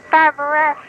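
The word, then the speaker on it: Fabray: She changed it because her aunt Nanette, and Nanette changed the spelling because Ed Sullivan called her Fabrette. So, and Nanette changed it to Fabrette, and it is pronounced Fabrette, but she wanted to make it, you know, look like.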